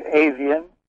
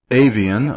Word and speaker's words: Ifan: Well, you'd say Avian. – Avian, okay. It looks nothing like that in spelling. – It's Welsh.